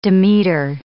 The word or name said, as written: Demeter